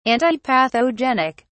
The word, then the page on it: antipathogenic: British English